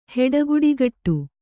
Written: ಹೆಡಗುಡಿಗಟ್ಟು

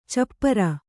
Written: ಚಪ್ಪಿರಿ

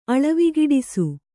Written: ಅಳವಿಗಿಡಿಸು

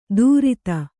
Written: ದೂರಿತ

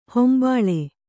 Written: ಹಂಬಲು

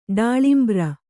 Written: ಡಾಳಿಂಬ್ರ

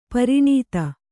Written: ಪೌರನೀತಿ

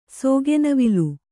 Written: ಸೋಗೆನವಿಲು

